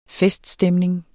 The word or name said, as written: feststemning